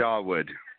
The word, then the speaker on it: Dawood: Dawood.